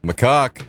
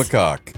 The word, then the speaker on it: macaque: Is that how you say it? – Yeah, macaque. – Yes. – It is macaque.